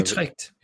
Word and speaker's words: Utrecht: Det er Utrecht.